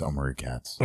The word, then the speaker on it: Omri Katz: Is that how you pronounce his name? He's from Hocus Pocus, Erie, Indiana, which is a fantastic little show that Joe Dante actually helped create. – Oh, yeah. I think it's Omri Katz.